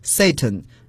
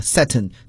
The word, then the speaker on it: Satan: 好，接下来看另外一个词 Satan 英国人呀把它读作 Satan，美国人呢有时把它读作 Satan，就是一种语言变体了。其实 Satan 都是对的。